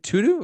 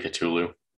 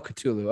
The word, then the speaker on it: Cthulhu: And the call of, is it Tutu or I don't know how to say it. – I'm pretty sure it's supposed to be Cthulhu. – The call of Cthulhu.